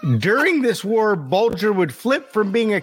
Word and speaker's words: Bulger: I googled it. It's Bulger.